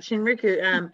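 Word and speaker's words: Shinrin-yoku: So Shinriku, did I say it right? – You're right. Shinriku.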